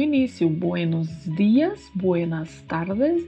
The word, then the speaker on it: tardes: O DE é pronunciado como de mesmo, tardes. Não se pronuncia com som de di, como acontece em muitas palavras no português.